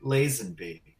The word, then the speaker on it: Lazenby: It's pronounced Lazenby.